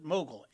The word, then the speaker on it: Mughal: When not buried in books about Mughal, I am going to pronounce it Mughal, M-U-G-H-A-L. I am pretty sure that's Mughal, like somebody who runs a big movie studio is a Mughal. – M-U-G-H-A-L? – Yeah.